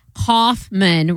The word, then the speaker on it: Kaufman: And Kaufman, pronounced Kaufman, rhyming with cough plus man.